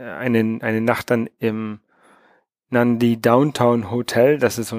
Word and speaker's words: Nadi: Das wird Nadi geschrieben, aber Nandi ausgesprochen.